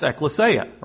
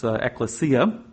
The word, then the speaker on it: ekklesia: The Greek word that the word church is translated from, and you could probably all say it together with me, it's ekklesia or ekklesia. Some pronounce it a little bit differently. I looked it back up, but it's ekklesia.